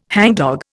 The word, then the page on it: hangdog: Indian